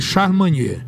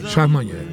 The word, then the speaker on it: Charlemagne: É, né, aí, aí é como você tem um Magne, aí você vai pronunciar em francês Charmagne, Manhã. Charmagne. Pronto, aprendi.